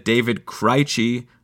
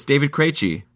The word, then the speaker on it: Krejci: Last week, I said David Krejci. I don't know why. It's David Krejci.